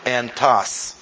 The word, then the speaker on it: entos: And that word is the word E-N-T-O-S, accent on the last syllable, entas. I'll stress it that way.